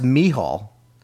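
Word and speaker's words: Micheal: In Ireland, it's M-I-C-H-E-A-L and is pronounced Michal.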